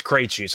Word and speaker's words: Krejci: It's pronounced Krejci, so I'm assuming it's pronounced the same way.